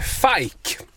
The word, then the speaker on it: Fike: Baby Doll, Dominic Fike, eller Fike, som det verkar vara så man ska uttala det.